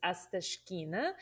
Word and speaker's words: Astashkina: Yeah, my name is Anna, and my last name is unpronounceable, but it's Astashkina.